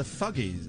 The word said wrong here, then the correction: Fugees: Fugees. – Wyclef Jean and Lauryn Hill, it says here.